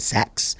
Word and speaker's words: Sachs: Well, that's how you say it, Sachs.